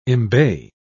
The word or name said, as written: embay